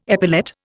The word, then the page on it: epilate: Indian